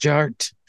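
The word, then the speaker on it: DJ Artt: And my name is Jared, aka DJ Art, with two T's for a double dose of that tink tink that D is silent, so it's just Jart.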